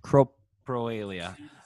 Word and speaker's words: coprolalia: I was doing some research and I came across where they explained, I think I'm pronouncing it right. Coprolalia. Is that right? What is it? – Coprolalia.